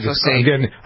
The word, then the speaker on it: glyphosate: There's a new study out that links Roundup and other products that have glyphosate. Is that how you pronounce it? I never pronounce it. – Yeah, right, glyphosate.